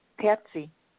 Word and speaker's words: Patsy: Hi, this is Patsy. – I'm sorry, what is your name again? – Patsy. – Patsy? – Pepsi? Patsy. – Kathy. No? – Yeah. – Spell it. – P-A-T-S-Y.